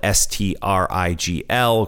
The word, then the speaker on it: Strigl: My last name is spelled S T R I G L.